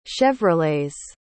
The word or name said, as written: chevrolets